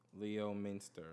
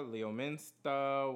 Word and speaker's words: Leominster: Leominster. – Leominster. – Leominster. Leominster. All right, so we got Worcester, Leominster.